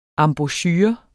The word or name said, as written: EMBOUCHUREN